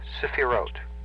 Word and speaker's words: Sephiroth: S-E-P-H-I-R-O-T-H. Now, the T-H is pronounced as a hard T. And the first E is a very short, very quick E. It's a. It's not long and drawn out. It's a very quick one. Sephirot. And the accent is on the last syllable.